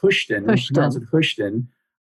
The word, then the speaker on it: Houston: Is the town Houston? Am I saying that right? – Well, I think it's pronounced Houston.